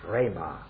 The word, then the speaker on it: rhema: And I forget whether I said yesterday that the word for words is this word rhema. It isn't the ordinary word logos. It's rhema, R-H-E-M-A, and it's a long E, a rhema.